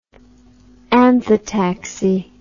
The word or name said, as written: anthotaxy